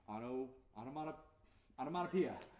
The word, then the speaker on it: onomatopoeia: And the second word is pronunciation, onomatopoeia.